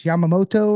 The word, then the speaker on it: Yamamoto: I think it's Yamamoto or something.